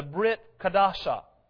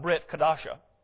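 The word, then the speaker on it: Brit Hadashah: The Bible consists of the Tanakh, that's the Old Testament, Torah, prophets, writings, and the what is commonly known as the Brit Kadasha, which is the New Covenant. Sorry, I mispronounced that. Brit Kadasha.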